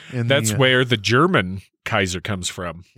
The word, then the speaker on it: Caesar: By the way, Caesar, again, with the hard C, it's actually pronounced Kaiser.